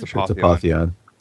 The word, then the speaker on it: Apotheon: It's Apotheon. – Apotheon. All right. So free multiplayer update for Steam only, I believe, not on the PS4 version. So just reading from the article itself, Apotheon Arena is a free online multiplayer complement to the mythic Greek action RPG Apotheon.